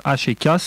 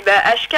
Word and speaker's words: Ashka: D'accord, et on va rappeler quand même l'écriture d'HK, c'est pas la lettre H et K, c'est HK, donc il y a une signification particulière ou pas? Ça s'écrit A-S-H-K-A, on va évacuer tout de suite les questions bateau.